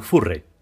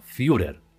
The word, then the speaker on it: Führer: Furre, que es como yo pronunciaba el título Führer de Hitler, hasta que me dijeron que se pronuncia Führer.